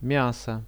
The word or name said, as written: miaso